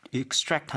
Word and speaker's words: extract: 왜 여기서는 fishing hook, 낚싯바늘, 그리고 cactus spines, 선인장 가시, 이거를 왜 extract 한다고 했을까? – Okay, so two things. As a noun, extract, stress on the first syllable, right.